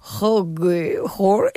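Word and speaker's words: hygge: Higgy? Però es pronuncia Higgy. És una paraula danesa que vol dir com slow living,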